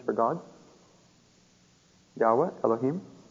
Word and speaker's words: Yahweh: Now, we don't have a real date for this, but a French fellow, yes, J-E-D-P, the J stands for Yahweh, that's God's name, Yahweh. A lot of people say Jehovah, but his name is Yahweh, is the way it's to be pronounced. Y-H-W-H.